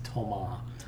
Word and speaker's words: Thomas: Nina and those around her, including, his name is actually Thomas, but it's spelled Thomas. I said Thomas before. It's Thomas.